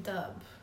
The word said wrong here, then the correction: Dubh: Dove. – Dove. Okay. – Yeah. – I've been saying Dub this whole time.